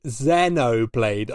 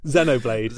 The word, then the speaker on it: Xenoblade: Xenoblade. I think it's Xenoblade, based on like the word xenophobic, because it's spelled with an X.